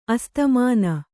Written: ಅಸ್ತಮಾನ

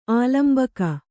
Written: ಆಲಂಬಕ

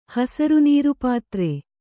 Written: ಹಸರುನೀರುಪಾತ್ರೆ

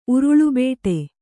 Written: ಉರುಳುಬೇಟೆ

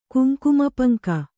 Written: ಕುಂಕುಮಪಂಕ

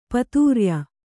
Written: ಪಿತ್ರ್ಯ

